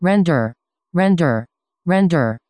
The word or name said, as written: render